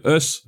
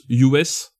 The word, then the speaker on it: Us: Voilà, c'est le us. D'ailleurs, lors de la promo, Jordan Peele prononce US euh, et non pas us.